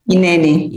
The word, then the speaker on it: Hineni: Hineni, that's how you pronounce that? – Hineni. – Hineni.